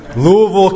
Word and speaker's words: Louisville: There may be an S in the spelling, but there is no S in the name. Louisville, Kentucky. If you're not from Louisville, you might see it and pronounce it Louisville, and that's just wrong. That's not how you say it at all.